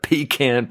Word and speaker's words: pecan: You get a lot of room for future expansion after that seventh piece of pecan pie. And yeah, that's how you correctly pronounce that.